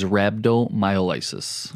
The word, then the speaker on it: rhabdomyolysis: Today's topic is rhabdomyolysis. – Nice. You said it right this time.